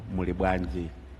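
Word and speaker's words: Muli bwanji: Yes, it is. You are pronouncing it properly. It's like 你好。So you're saying morning, Mulibwanji. Mulibwanji is ni hao.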